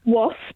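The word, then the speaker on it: Waspe: Okay, so it's Wasp, as in the insect, but with an E on the end.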